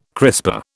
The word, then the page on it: crisper: British English